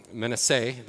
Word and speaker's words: Manasseh: And then our last message, we spent some time walking through these 2.5 tribes of Reuben, of Gad, and Manasseh, or Manasseh, the Hebrew pronunciation.